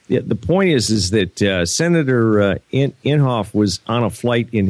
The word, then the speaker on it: Inhofe: one of the sponsors was Senator is it Inhofe? I can never pronounce his name. – I think so. – Inhofe.